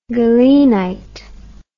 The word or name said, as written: galenite